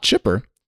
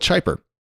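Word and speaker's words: Chiper: In this case, it's C-H-I-P-E-R, which is not Chipper, nor is it Chipper. We're going to go with Chyper.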